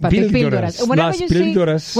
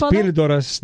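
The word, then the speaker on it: Las Píldoras: Did I say it right? – Say it again. – Las Pildoras. – Pildoras. – Las Pildoras. – Whenever you see un acento, el hyphen and everything. – Las Pildoras.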